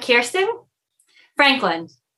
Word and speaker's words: Kirsten Franklin: Okay, Franklin, two syllables. And here's the reason. I know some people have many syllables to their name. And if we put them all together, it's not repeatable, which means it's not memorable. So if we enunciate punch, punch, both syllables, it lands in a way that later on in that day, they can say, oh, who's that?